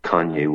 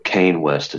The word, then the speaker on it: Kanye: or Kane West, as some want to pronounce it, at Trump's Florida estate. So Nick made the news. – Yeah. Well, we got one particular boomer to manage to stop saying Kane West and start saying Kanye.